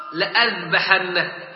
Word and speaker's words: لأذبحنه: وكذلك لأذبحنه، لأذبحنه، نجد أن لام ألف وهمزة على الألف ثم ألف بعدها، لكن على الألف الذي بعدها صفر مستديرة وتقرأ لأذبحنه، أسقطنا ذلك الألف لا يقرأ.